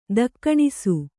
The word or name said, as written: ದಕ್ಕಣಿಸು